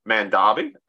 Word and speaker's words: Mandabi: And now we're going to move on to Mandabi, directed by Usman Sembin.